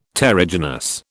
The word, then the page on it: terrigenous: British English